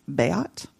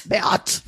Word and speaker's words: Beat: So the name Beat, which you wouldn't think would actually be a name, but there actually is a German name that's spelled like that, but it's pronounced Beat. – Beat. – Yeah, it probably does sound more like that, which means blessed or happy or bringer of joy.